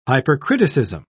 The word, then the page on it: hypercriticism: British English